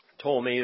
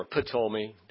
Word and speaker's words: Ptolemy: The king of the north in Syria was named, their last name was Seleucid, and the ones in Egypt were the Ptolemies, P-T-O-L-M-E-Y, I think. Ptolemy or Ptolemy, depending on if you have ever had pneumonia or not, you can pronounce the P or let it be silent.